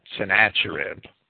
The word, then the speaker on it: Sennacherib: When the Assyrians under Sennacherib, some people pronounce that Sennacherib, had decimated Judah and threatened Jerusalem, Hezekiah became sick unto death, as it is described in 2 Kings chapter 20.